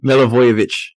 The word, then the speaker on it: Milivojevic: Milivojevic. That J is silent.